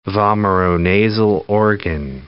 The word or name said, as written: vomeronasal organ